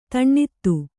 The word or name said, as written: ಧನದ